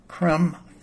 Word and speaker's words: crumb: Then we have the U-M words with a silent B at the end, crumb, thumb, and dumb.